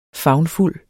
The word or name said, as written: favnfuld